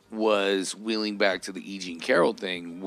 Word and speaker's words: E. Jean Carroll: Right, and it's my understanding, it's not Jeanine Carroll, it's E. Jean Carroll. Emphasis on the wrong syllable.